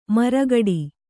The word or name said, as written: ಮರಗೋಡು